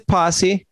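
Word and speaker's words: Pasi: Adam Passy's going to, am I saying it right? Passy? – It's Posse. Yeah, Adam Posse. Yeah. He's... – Oh, God. I wish he spelled it P-O-S-S-E, but it's P-A-S-I.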